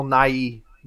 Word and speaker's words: Nighy: it's fucked up that his name is, like, his last name is two syllables. – Yeah. – Bill Nighy.